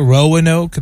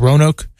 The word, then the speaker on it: Roanoke: A lot of people would call it Roanoke. They really emphasize the the pronunciation of it, but it's just Roanoke.